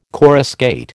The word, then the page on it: coruscate: American English